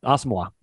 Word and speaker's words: Asamoah: Asamoah. That's that's how we're pronouncing it now.